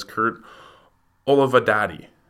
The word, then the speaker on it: Olivadotti: and that is Kurt Olivadotti. I got that right. – Yeah, had to look that one up to pronounce it correctly.